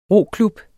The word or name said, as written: roklub